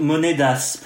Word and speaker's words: monedas: Hein depuis le début, je me dis, il le prononce super mal. super. Je parle pas italien, moi, donc voilà. 30 monedas, pour être exact qu'on peut traduire pour 30 euh, pièces de monnaie en fait.